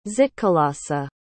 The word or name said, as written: zitkalasa